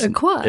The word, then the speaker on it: Aquai: And then Aquai is spelled A-Q-U-A, so Aqua, A-I. And so I keep calling it Aqua A-I. And obviously it's Aquai.